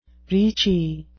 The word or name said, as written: reechy